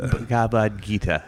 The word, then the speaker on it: Bhagavad Gita: The Bhagavad Gita. – Bhagavad Gita, yeah.